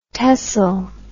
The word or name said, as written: tesl